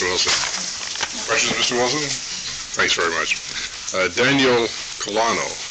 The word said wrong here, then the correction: Colang: Good afternoon. – My name is Daniel Colang. I am a DOC. – That's okay. – Would you spell that, please? – C-O-L-A-N-G. – C-O-L-A-N-G.